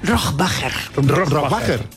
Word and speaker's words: Rohrbacher: Bueno, ¿cómo se pronuncia Alba Rohrbacher Cochet? – Rohrbacher.